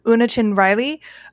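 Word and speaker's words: Una Chin-Riley: One by one, the entire crew is incapacitated except for number one, Unichin Riley. I'm not sure if I'm saying that right. – You got it. No, you nailed it.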